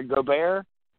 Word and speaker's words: Gobert: Gobert, Rudy Gobert, is that, I believe that's his name.